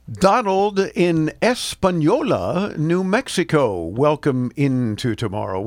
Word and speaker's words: Española: Española, I know how to say that.